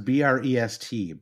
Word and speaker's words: Brest: Yeah, he's joining, by the way, from Brest. Is that how you say that? I'm not sure. It's pronounced Brest, Brandon.